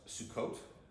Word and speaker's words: Sukkot: It's pronounced Sukkot. That's how you say it in Hebrew.